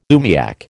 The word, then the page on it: umiak: American English